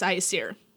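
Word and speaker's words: Æsir: The A-E is that fancy A-E that's like one letter. Mm. So it's Aesir.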